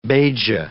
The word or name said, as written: Beja